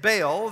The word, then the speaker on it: Baal: We say it Baal, and that's how you actually pronounce it.